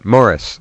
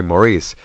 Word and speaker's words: Morris: Yeah, now you pronounced it correctly, too. Morris. – Yeah. – Americans say Maurice.